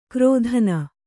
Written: ಕ್ರೋಧನ

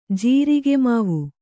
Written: ಜೀರಿಗೆಮಾವು

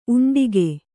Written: ಉಂಡುಕ

